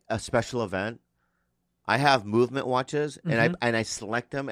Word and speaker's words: MVMT: It's pronounced movement.